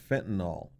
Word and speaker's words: fentanyl: And it's pronounced fentanyl, F-E-N-T-A-N-Y-L, fentanyl.